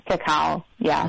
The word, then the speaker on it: cacao: And then some cacao. How do you say it? Cacao? – Cacao, yeah.